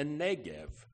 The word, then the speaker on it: Negev: Restore our fortunes, O Lord, like streams in the Negev. The B is actually pronounced like a V, if you want to impress your Christian friends.